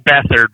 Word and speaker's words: Beathard: Is it pronounced Beathard or Beathard? – It's Beathard, but it will never not be Beathard for me.